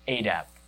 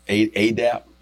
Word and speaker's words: ADAP: So, is it ADAP? That's the way you pronounce it? That's how you do it? – ADAP. – ADAP? – That's right.